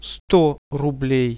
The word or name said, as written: 100 rublei